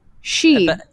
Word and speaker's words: Xi: She. – Yeah, the X is like kind of like an SH noise.